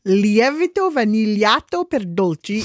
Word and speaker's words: Lievito vanigliato per dolci: How do you pronounce this? – Lievito vanigliato per dolci.